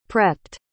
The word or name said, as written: prepped